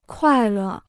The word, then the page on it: 快乐: kuài lè